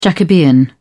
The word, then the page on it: Jacobean: British English